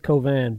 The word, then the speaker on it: Cauvin: Covan. – J.L. Covan. – C-A-U-V-I-N. It's not spelled the way it sounds. You pronounce it Covin, but it's C-A-U-V-I-N.